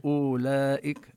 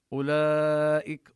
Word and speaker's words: أولئك: أولئك هنا الواو وإن كان رسمت لكن ليس لها تأثير في النطق، لا نقول أولئك، إنما وضعوا عليها هذه الدائرة الصغيرة لبيان أنها تكتب لكن لا تلفظ. أولئك، ونمد الألف أربع حركات، مد متصل واجب.